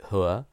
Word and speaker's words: Ho: Well, I must say, in normal conversation, I would just say Dirty Ho, but I had decided to make a conscientious effort to say Hu, because that is the proper pronunciation. It's a Chinese name, but also it's just spelt H-O in its anglicization.